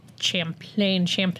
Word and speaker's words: Champlain: In 1980, they rented space in an old spool and bobbin mill in South Champlain Street.